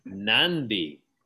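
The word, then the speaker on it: Nadi: In Fiji, and that's how you pronounce it. – Nandi.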